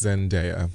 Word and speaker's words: Zendaya: Yes, I realize I said her name incorrectly 500 times last week. – Oh, it's not Zendaya? – Apparently it's Zendaya.